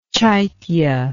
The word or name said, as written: chaitya